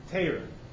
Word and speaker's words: Torah: It wasn't Torah, it was Teira. It was A. An O had the pronunciation of an A.